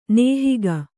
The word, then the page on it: ನಿಹಾಕೆ: nihāke